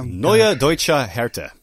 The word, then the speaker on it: Neue Deutsche Härte: known as New Deutsche Härte. I don't know if that's how it's pronounced, but... – Neue Deutsche Härte. – Okay, there we go.